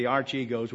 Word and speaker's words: Archegos: A-R-C-H-E-G-O-S.